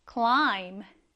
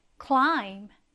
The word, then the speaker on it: climb: climb. climb. 이때 마지막 글자인 b는 거의 발음하지 않는다라고 보셔도 됩니다. 그래서 climb 이렇게 발음이 되는 거고요.